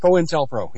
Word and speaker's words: COINTELPRO: and I think they're co-pro, Intel Pro. How do you pronounce that? – COINTELPRO, yep.